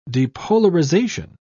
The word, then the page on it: depolarization: British English